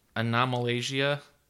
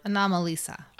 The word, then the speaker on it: Anomalisa: Anomalasia? I've seen a preview for it. – Anomalisa. – Anomalisa.